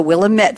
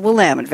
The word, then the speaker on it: Willamette: And the Willamette Valley is not the Willamette Valley. It's the Willamette Valley. So there. If anybody wants to look it up, go to Argyle, A-R-G-Y-L-E.